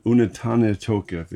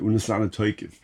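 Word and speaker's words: Unetaneh Tokef: So, there's a prayer called, how do you pronounce it? Unetane Tokev. They say Unesane Tokev, you know, the way the Ashkenazi.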